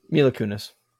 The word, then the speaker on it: Mila Kunis: Mila Kunis.